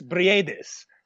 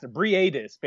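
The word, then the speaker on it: Briedis: It's Maris Bredis. So you got to pronounce both the I and the E in the last, Bredis, basically.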